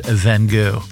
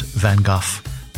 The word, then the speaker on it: van Gogh: We're told, of course, the proper pronunciation is Vincent van Gogh. But I think most people say Vincent van Gogh. That's how we said it when we were younger and we've never changed it.